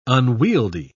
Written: unwieldy

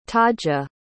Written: taja

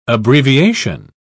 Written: abbreviation

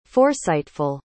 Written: foresightful

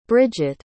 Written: bridgit

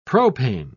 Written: propane

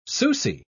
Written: susi

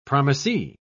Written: promisee

